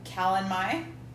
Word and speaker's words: Calanmai: So we've got Calanmai. – Okay, we're saying that right. – You're right. – Or Calanmai. I don't know. It's M-A-Y is the last one. M-A-Y.